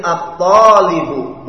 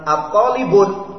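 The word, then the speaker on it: at-talibu (الطالب): Kemudian talibun, dimasukkan alif lam menjadi at-talibu, bukan at-talibun.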